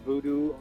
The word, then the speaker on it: Voodou: Is that pronounced Vujo, or how do you pronounce that word? – Well, it's just another spelling of the word voodoo, you know, voodoo, which is a Haitian kind of a healing art, you know, what they call voodoo, you know, they have voodoo dolls. It's just another, it's kind of like the original spelling of it, or the French Haitian spelling of it. – Well, and I was wondering, because it's spelled on the CD V-O-O-D-O-U, and I'd never heard that before.